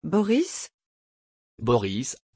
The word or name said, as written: boris